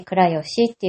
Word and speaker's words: 倉吉: いて、で、その後日本に戻ってきて、ずっとまた東京に住んでいたんですけれども、今年の 4 月に鳥取県の倉吉市というところに引っ越してきました。倉吉というのは倉庫の蔵に大吉の吉と書いて、え倉吉っていう風に読むんですけども、